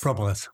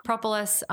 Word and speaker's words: propolis: Propolis. – Propolis. – Propolis.